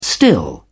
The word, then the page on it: still: British English